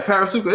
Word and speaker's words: Parasuco: That's how you say it, right? Parasuco. Yeah, P A R A S U C O.